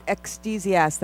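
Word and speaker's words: ecdysiast: Ecstasiast.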